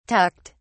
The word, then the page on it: tucked: British English